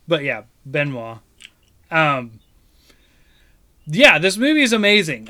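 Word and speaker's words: Benoît: You pronounce it Philippon? Benoit. – Benoit. – Like, okay. Like Benoit. Like the Arthur joke.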